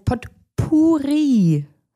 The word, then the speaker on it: Potpourri: Es heißt Potpourri. Ein Potpourri. – Wie schreibe ich das? – Genau, so, ich habe jetzt auch selber nochmal nachgeguckt, war auf der richtigen Fährte, da schreibt man P-O-T-P-O-U-R-R-I. Potpourri.